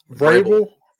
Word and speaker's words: Vrabel: Vrabel.